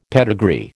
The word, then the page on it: pedigree: American English